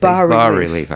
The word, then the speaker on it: bas-relief: on the over door of the doorway is a sculpture, a base relief sculpture. Is that how you say it? – Bas-relief.